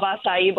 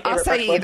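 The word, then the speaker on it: açaí: I love acai bowls. – Yeah, acai, that's how you say it. I can never say that word right.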